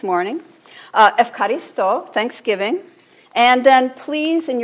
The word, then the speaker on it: Ευχαριστώ: But you don't say Eucharist. You say Ευχαριστώ. Ευχαριστώ. Now, do you see an F in there, in the Greek? But that's how they say it, Ευχαριστώ.